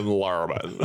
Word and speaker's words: Lerman: With the back of your throat. Lerman.